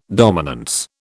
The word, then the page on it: dominance: British English